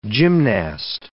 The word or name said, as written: gymnast